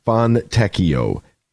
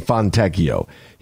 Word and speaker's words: Fontecchio: Then, yesterday, the big one is Simone Fontecchio. That's how you say it. Simone Fontecchio.